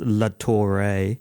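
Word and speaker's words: Latore: George Latour, I'm sure it's pronounced Latouré, a.k.a.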